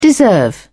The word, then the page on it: deserve: British English